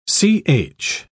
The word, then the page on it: ch: British English